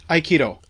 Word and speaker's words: Aikido: And tell me if I pronounce these wrong. Aikido? – Aikido. – Aikido. Aikido. Okay.